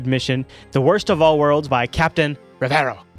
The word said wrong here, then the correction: Revo: Right? Am I saying that right? – Captain Revo. – Revo! While Worst of All Worlds didn't receive the best reviews, this sequel definitely has shown higher quality than the previous one.